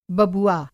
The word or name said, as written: बबुआ